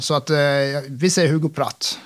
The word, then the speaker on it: Pratt: Alltså, vi säger Hugo Pratt.